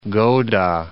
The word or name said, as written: Gowda